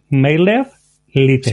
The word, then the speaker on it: MailerLite: Y entonces me cambié a MailerLite y genial. – Pues esa no sé cuál es, ¿cómo se dice? – MailerLite. – Sí. Ah, MailerLite.